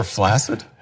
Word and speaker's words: flaccid: See, the correct pronunciation is flaccid, but it's falling out of favor. And so eventually, 20 years, you know, or 100 years from now, no one will say flaccid.